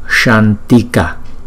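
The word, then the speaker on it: śāntika: À, chữ S có dấu sắc chúng ta đọc là S. Và chữ A có dấu gạch ngang chúng ta đọc kéo dài hai nhịp. Shantika, Shantika.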